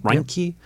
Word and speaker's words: Reinke: Tony Reinke. Is that how that's pronounced? Reinke. Yep.